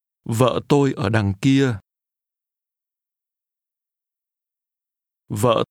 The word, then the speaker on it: vợ: Vợ tôi. Vợ. Vợ. Vợ tôi. – In Vietnamese, the word for wife is pronounced with a low, falling, broken tone.